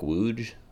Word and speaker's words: Łódź: And then I was in Bialystok, Poland for 2 days, Warsaw for 2 days, Łódź for 2 days, or like Łódź. I think it's really cute. It's spelled L-O-D-Z, but with like a bunch of weird like letters that we don't actually have.